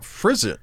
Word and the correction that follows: frisson: Frisson. Sorry, it is pronounced frisson.